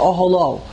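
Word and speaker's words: Oholo (אהלה): The word Oholo is spelled with a He, and we pronounce it with a Vav.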